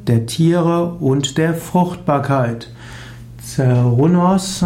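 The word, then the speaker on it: Cernunnos: Zerrunos. Zerrunos, auch ausgesprochen Kerunos, meistens geschrieben Zernunos, ist der Name eines keltischen Gottes.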